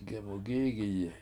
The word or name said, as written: gemugIIgiyi